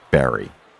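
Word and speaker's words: Barre: That's B-A-R-R-E, pronounced Barrie.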